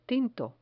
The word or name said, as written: tinto